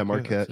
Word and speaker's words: Marquette: Is it Marquette? – Yeah, Marquette.